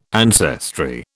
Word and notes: ancestry: British English

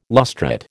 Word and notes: lustrate: American English

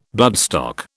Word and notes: bloodstock: British English

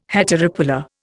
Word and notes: heteropolar: Indian

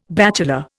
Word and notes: bachelor: Indian

